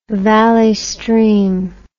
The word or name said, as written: Valley Stream